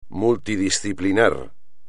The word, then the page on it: multidisciplinar: espagnol